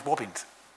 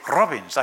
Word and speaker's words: Robins: I couldn't roll my R's. My name was Wobbins. And it wasn't until I learned Spanish that I now can say robins. I can actually say it properly.